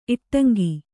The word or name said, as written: ಇಟ್ಟಂಗಿ